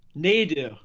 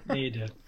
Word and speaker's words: Nadu: Nadu. Everyone Nadu. – always gets the pronunciation wrong. Yeah, Nadu.